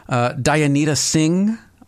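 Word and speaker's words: Dayanita Singh: Her name, and I hope I don't butcher this, Dianita Singh. I believe is. Would you see? That's how you pronounce that? – I think that's pretty close.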